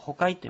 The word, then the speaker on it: 行器: はい、あのまあアイヌ文化のことを、勉強されている方ご存知かと思いますけど、宝物として使うあの入れ物です。日本語ではあのホカイと言います。行く器と書いて行器と呼ぶ、行器と書いてあのホカイというんですが、このホカイという道具があります。